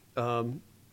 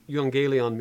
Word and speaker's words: euangelion: And I'll get to that just in a second, but euangelion, mm-hmm. if I'm saying that correctly, is where we get the word evangelization there.